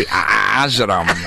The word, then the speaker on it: Ajram: Well, it begins with the letter A-N, which you guys don't have in English. So her last name is Nancy Ajram. Can you say that? – Ajram.